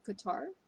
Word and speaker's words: Qatar: They're over in Qatar. Qatar, depending on what country you're from, is how it's pronounced, and their internet's out.